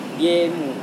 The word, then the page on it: diemu: Serewen